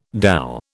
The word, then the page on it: dhow: British English